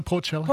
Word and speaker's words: portulaca: And this one, I reckon a good plant for this season will be the perennial portulaca. Mm. Am I saying that correctly? – Yeah, portulaca because it's succulent.